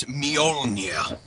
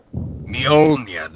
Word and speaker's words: Mjolnir: I'd just like to correct you. It's Mjolnir. – Mjolnir? – Mjolnir. That is how it's pronounced, yes. – As in Me Old, but without the D?